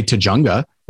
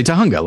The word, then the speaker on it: Tujunga: but then no, it's like, we don't say Tujunga, we say Tahunga. Like, that's the correct pronunciation.